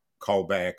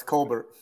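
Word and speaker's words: Kolbert: She's not like Colbert, but no, it's Colbert.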